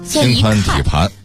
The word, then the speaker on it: 心宽体胖: 所以读音呢就会有点不太一样。 – 心宽体盘。 – 是胖这个字儿，但不读这个音。